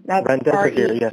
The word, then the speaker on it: Remdesivir: The one you also mentioned, is it Rezetivan? Is that how you pronounce that? – Remdesivir. – Remdesivir, yeah.